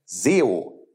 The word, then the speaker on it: SEO: Das jiddische Wort für Maßstab oder Norm ist nämlich SEO, geschrieben S-E-O. Das spricht man so ähnlich aus wie Sau.